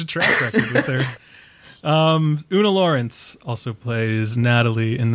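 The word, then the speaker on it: Oona: Una? Is that how you say it? Una? – I think so. – That's the only way to say it, really.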